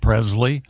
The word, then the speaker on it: Presley: Happy birthday, Priscilla Presley. She was 14 when she met Elvis. Is that right? – Why are you mispronouncing Presley? – What did I say? – Presley. – It's a hard Z.